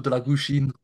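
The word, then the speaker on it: Drăgușin: Obviously he's linked with Spurs, but then you've gone and got Dragushin, I think that's how you pronounce it. – Yeah, Vlado Dragušin.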